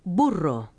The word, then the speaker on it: burro: Double R's should be rolled like this. Rrr. – Burro.